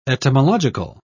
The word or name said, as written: etymological